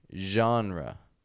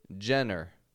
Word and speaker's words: genre: You might see the word genre, spelled G-E-N-R-E, genre. Okay, now, of course, maybe you've pronounced this Jenner. I hear that often, Jenner. We don't want to say Jenner or anything like that, but genre.